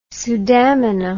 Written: sudamina

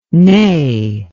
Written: nae

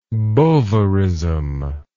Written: bovarism

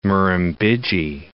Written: Murrumbidgee

